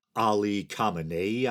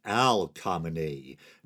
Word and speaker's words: Khamenei: But while discussing that story, I mentioned Iran's supreme leader, Ali Khamenei, I believe it is, but I accidentally said Al Khamenei.